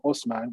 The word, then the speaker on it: Osman: They start off with, as we all know, from one of their early chieftains or early leaders named Osman, which is the Turkish pronunciation of the Arabic name Othman.